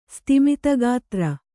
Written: ಸ್ತಿಮಿತಗಾತ್ರ

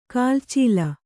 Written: ಕುಲಾಚಲ

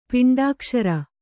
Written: ಪಿಂಡಾಕ್ಷರ

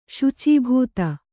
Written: ಶುಚೀಭೂತ

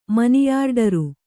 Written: ಮನಿಯಾರ್ಡರು

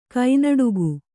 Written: ಕಣ್ಣಡಿಗ

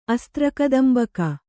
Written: ಅಸ್ತ್ರಕದಂಬಕ

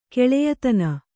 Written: ಕೆಳೆಯತನ

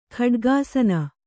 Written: ಖಡ್ಗಾಸನ

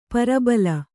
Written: ಪ್ರಬಲಿ

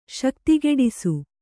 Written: ಶಕ್ತಿಗೆಡಿಸು

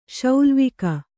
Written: ಶೌಲ್ವಿಕ

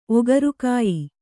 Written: ಒಗರುಕಾಯಿ

